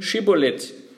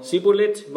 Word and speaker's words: Schibolet: Wenn er dann antwortete, nein, ließen sie ihn sprechen Schibolet. Sprach er aber Sibolet, weil er es nicht richtig aussprechen konnte, dann ergriffen sie ihn und erschlugen ihn an den Furten des Jordans, sodass zu der Zeit von Ephraim fielen 42.000.